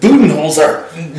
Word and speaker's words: Budenholzer: Budenholzer.